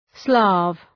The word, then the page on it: Slav: slæv